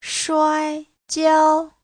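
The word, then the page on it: shuaijiao: shuai1jiao1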